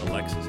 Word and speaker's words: Alexis: First name looks like Alexis, it's pronounced Alexi.